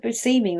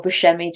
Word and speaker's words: Buscemi: Steve Buscemi was actually asked to be, or Buscemi, depending on how you say it, was up for Renfield.